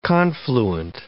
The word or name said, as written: confluent